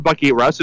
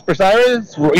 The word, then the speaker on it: Bucyrus: Well, it's actually not tomato, tomato, because there is a proper pronunciation for the town of Bucyrus, and it's called Bucyrus. Bucky Russ is not even close.